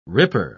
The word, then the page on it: ripper: British English